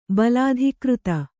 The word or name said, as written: ಬಲಾಧಿಕೃತ